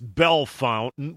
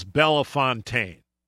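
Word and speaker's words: Bellefontaine: He says it's pronounced Belle Fountain. Well, they spell it Belle Fontaine. How was I supposed to know? Even Mary, who's the English whiz, thought it was Belle Fontaine.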